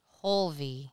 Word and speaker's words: Hulvey: Hulvey.